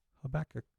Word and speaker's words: Habakkuk: So Habakkuk. That's it. – Habakkuk.